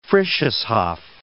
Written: Frisches Haff

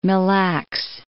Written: Mille Lacs

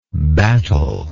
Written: batteler

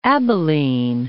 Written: Abilene

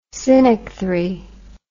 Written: synechthry

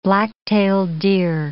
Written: black-tailed deer

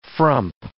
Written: frump

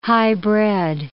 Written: highbred

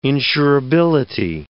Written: insurability

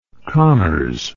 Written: connors

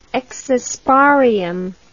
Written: exosporium

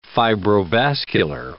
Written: fibrovascular

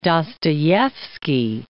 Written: Dostoyevsky

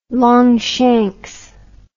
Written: Longshanks

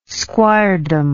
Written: squiredom

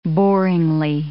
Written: boringly

